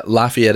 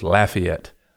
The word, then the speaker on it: Lafayette: So I've always had the pronunciation thing with my hometown. So not Versailles, Versailles, Lafayette. Yeah. – That's right. Yeah. Well, I was in Louisiana and it was Lafayette.